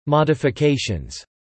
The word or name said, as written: modifications